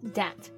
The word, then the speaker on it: debt: 那刚刚讲的他的这个财务状况不太乐观啊，就是首先呢，他有一个十亿的美元欠账，而且现在的家族生意也面临了极大的危机。那我们说到欠款嘛，债务都是用到这个词 debt。那这个单词发音的时候要注意一点，它的拼写是 d e b t，但是中间的字母 b 是不发音的，直接读作 debt。 – Debt.